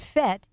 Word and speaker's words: Fete: It's spelled A-L-E-X, and Fet is F-E. T-E, and we want you to get set for the Fet.